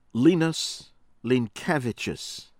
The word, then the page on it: Linas Linkevicius: American English